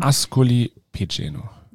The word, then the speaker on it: Ascoli Piceno: Ascoli Piceno. – Ja, sehr gut ausgesprochen.